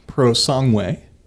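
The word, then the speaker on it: Purosangue: But since we shifted to Ferrari, the next one is the Ferrari Prosangue, I believe is the correct way of pronouncing that.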